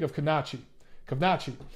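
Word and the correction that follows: Kownacki: So other than the fact that I consistently butcher his name, you know, I like Adam Kovnacki.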